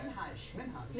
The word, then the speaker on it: Minhaj: Minhaj. – Minhaj. – So the emphasis on the H.